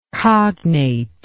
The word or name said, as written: cognateness